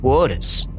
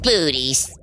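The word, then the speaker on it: Boötes: Arcturus is in the constellation of Bootes. – Booties. – Bootes. – It says booties on the teleprompter. – It's pronounced Bootes.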